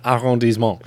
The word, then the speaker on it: arrondissement: Arrondissement. – There we go. Thank you. – Feels good saying that. Arrondissement.